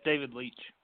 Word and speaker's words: David Leitch: Yeah, it's David Leitch.